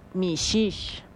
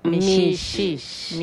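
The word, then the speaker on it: mixix: Pijije en Yucatán se dice mishish. – Mishish.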